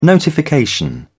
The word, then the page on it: notification: British English